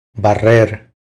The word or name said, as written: Barrer